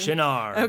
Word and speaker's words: Shinar: Shinar.